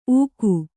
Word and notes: ಊಗು: ūgu

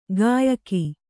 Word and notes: ಗಾಯಕಿ: gāyaki

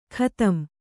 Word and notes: ಖತಂ: khatam; kannada

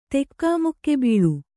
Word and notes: ತೆಕಿಮುಕ್ಕಿಬೀಳು: tekimukki bīḷu

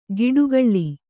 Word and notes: ಗಿಡುಗಳ್ಳಿ: giḍugaḷḷi

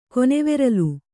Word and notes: ಕೊನೆವೆರಲು: Kannada

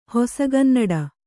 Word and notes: ಹೊಸಗನ್ನಡ: kannada

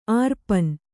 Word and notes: ಅರ್ಪಣ: arpaṇa

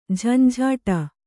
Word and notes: ಝಂಝಾಟ: Kannada

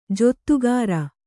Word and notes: ಜೊತ್ತುಗಾರ: jottugāra

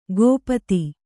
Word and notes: ಗೋಪತಿ: gōpati

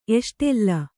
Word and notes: ಎಷ್ಟೆಲ್ಲ: kannada

